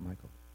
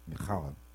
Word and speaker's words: Michal: Michal? No, it's not Michael. I looked it up. It wasn't Michael. You go, Michal.